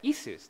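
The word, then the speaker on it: Isus (Иисус): But it's Isus. This is the name of Jesus in Russian, Isus.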